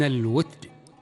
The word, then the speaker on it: الوتد: البداية من الوتد، ويلفظ بكسر التاء وسكونها.